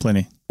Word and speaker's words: Pliny: How do you do it? – Pliny. – Pliny. – That's how I say it.